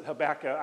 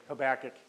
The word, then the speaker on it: Habakkuk: He pronounced it Habakkuk. I pronounce the K, Habakkuk.